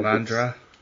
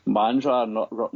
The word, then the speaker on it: Mandurah: I have to correct you on the pronunciation of Mandurah, mate, because Mandurah. – Mandurah, not Mandurah.